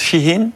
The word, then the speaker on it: Shein: Je ne sais pas comment on prononce, Shein, exactement.